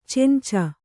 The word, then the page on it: ಚೆಂಚ: Kannada; cenca